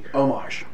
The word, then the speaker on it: homage: Homage. H is silent.